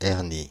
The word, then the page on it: breach: [briːtʃ]; [bɹiːtʃ]